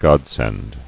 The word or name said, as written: Godsend